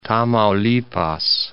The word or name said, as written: Tamaulipas